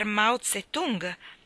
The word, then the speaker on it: Mao Zedong: Mr. Mayo? Si intromise Anne, precipitandosi a offrire da bere al suo connazionale, poiché conosceva il mio brutto carattere. Suppongo vogliate dire Mr. Mao Zedong? Ah, è così che si pronuncia, si stupì Myrtleton.